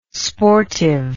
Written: sportive

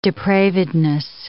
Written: depravedness

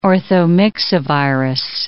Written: orthomyxovirus

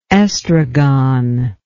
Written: estragon

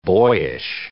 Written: boyish

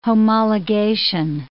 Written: homologation

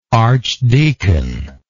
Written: archdeaconship

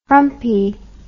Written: frumpie

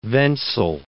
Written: Wenzel

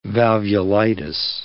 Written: valvulitis

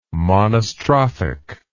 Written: monostrophic

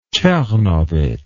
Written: czernowitz

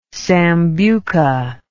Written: sambuca

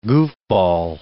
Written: goofball

